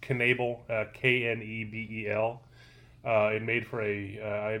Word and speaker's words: Knebel: Knebel.